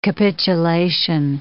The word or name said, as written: capitulation